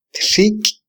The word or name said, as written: txiky